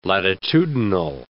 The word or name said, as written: latitudinal